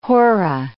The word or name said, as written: hora